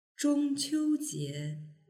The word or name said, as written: zhongqiujie